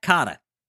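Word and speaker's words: Kotter: He is tackling his first TV role since Welcome Back, Cotta. Did I say that right? – You did. Mr. Cotta.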